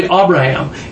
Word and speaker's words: Abraham: Okay, we all just pronounce it Abraham to include myself, but it's actually Abraham is the correct pronunciation, because that's father.